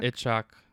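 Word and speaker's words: Itzhak: And that's when he starts kind of making his move as well, especially with Ben Kingsley's character, which I don't know how to say it. Ishak? – Itchak Stern. – Itchak Stern. – Yeah. – Is how I would say that.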